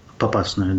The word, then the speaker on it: Попасную: И поэтому российская, ну, армия, она разрушает, разрушила Мариуполь, разрушила Попасную, извините, если я ударение неправильно ставлю. – Попасную, да, разрушила Северодонецк почти, много других поселков, городков уничтожила.